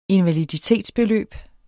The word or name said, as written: invaliditetsbeløb